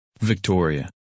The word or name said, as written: Victoria